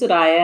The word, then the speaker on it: Soraya: So my name's Soraya and it rhymes with papaya.